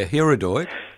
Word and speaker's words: Hirudoid: I mispronounced it at the pharmacy, the cream you recommended for my bruise. – Yeah, Hirudoid. – Yeah. – What did they give you? – I think I said hemorrhoid cream.